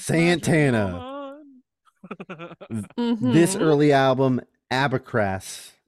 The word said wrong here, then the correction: Abraxas: Is that how you spell it? A-B-R-A-X-A-S. – Abraxas.